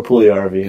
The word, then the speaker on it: Puljujärvi: Dave, what do you think, Laine or Puljujärvi? And I hope I said that right this time, Jake. – Yes, you did. – You're correct this time.